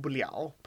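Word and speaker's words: liao (了): The character if you see it on screen here, the le in liao, pronounced liao. And we do actually go over this in a later lesson, but yes, that is what happens there.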